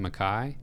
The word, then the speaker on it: Mekhi: And I'm committing to the pronunciation Makai. – I believe we have a Mekki Wingo, who's in this most recent Mizzou class, yeah. but we're going with Makai on this one.